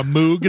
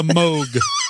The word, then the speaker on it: Moog: It's not a Moog. It's a Moog.